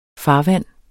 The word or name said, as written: farvand